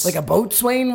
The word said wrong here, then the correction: boatswain: whistle? – A bosun whistle. – Whoops. Oh, I said it wrong.